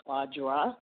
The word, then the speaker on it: Audra: And first off, just want to correct the pronunciation of my name is Audra.